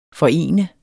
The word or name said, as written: forene